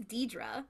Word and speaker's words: Deirdre: It is Deirdre. – It's Deirdre.